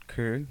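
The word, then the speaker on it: Köln: Is that how you say it? – Kern, yeah. – How does Cologne become Kern? – Because when we spell it, yeah. It's just K-O-L-N when you spell it with, oh, the German alphabet.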